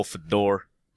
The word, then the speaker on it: Fedor: Lil Fedor. – Yeah, Lil Fedor. MMA. – Lil Fedor. Not like a hat. – No. – like the fighter, Lil Fedor.